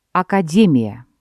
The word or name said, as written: академия